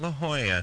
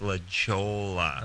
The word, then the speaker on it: La Jolla: They pronounce that La Jolla. – Beg your pardon. – Yes, it's not La Jolla. It's La Jolla.